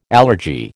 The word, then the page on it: allergy: American English